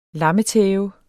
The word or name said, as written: lammetæve